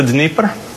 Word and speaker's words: Dnieper: Kiev is on this river that rhymes with deeper. – What's the Dnieper? – Yes.